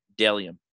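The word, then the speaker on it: Bdelliom: That's B-D-E-L-L-I-O-M, pronounced Delium.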